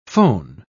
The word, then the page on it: fohn: British English